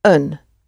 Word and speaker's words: een: The word for a or a good book, a good book, is een. Double E-N. Een. It's really one. Een. But if it's a, it is pronounced un.